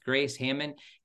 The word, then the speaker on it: Grace Hamman: Again, we're talking here, in case you're just coming in here, with Jesus Through Medieval Eyes with Grace Hamman.